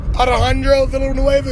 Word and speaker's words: Alejandro Villanueva: Villanueva Alejandro Villanueva is how you say his name.